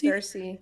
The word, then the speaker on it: Circe: is that how you pronounce it? Circe? – Circe.